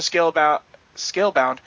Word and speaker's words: Scalebound: In another tweet, Spencer said the cancellation of Scalebound, you know, every time I say that, I think I'm saying it wrong, but I'm saying it right.